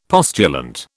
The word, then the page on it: postulant: British English